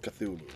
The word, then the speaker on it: Cthulhu: Call of Cthulhu, right? Yep. Is that how you pronounce it? Yep. Thank you.